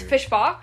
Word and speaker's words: Fishbach: Mark Fishback and... Nestor. – It's Fishbach, actually. Not Fishback.